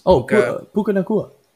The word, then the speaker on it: Puka Nacua: Well, his first name is spelled Puka. – Yeah. – Puka. – Yeah. – Okay, and then his last name is Nacua. So I guess it's Puka Nacua.